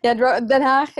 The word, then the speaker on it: Den Haag: Den Haag. I don't know if I'm pronouncing it right. – Yeah. Den Haag is like, then yeah, The Hague is my hometown, and yeah, that's part of the name.